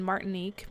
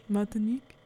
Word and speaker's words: Martinique: Martinique. No, it's French, so yeah. – It just feels like you should. – That's where they are. – Put a little stank on it.